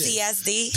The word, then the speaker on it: PTSD: say you had the PSD, right? Am I saying it right? PSCD? – Yep, it's just like that. – Yes. Whatever you got, say you got that stuff and you get out of this PTSD.